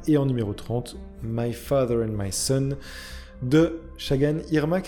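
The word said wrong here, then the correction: Çağan Irmak: Shahan Irmak, c'est ça Ouais, on prononce pas le G en fait.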